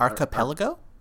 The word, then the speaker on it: Archipelago: And the single is called Arpilego. Is that how you pronounce that? – Is it Archipelago? – That's the one. Yeah. Okay. It's like, yeah, that's the word.